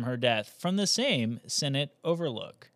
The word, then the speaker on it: Sinnott: And Senate is spelled S-I-N-N-O-T-T, so it's not like the Senate. You know, that legislative body that we all have so much confidence in. It's somebody's last name spelled completely differently.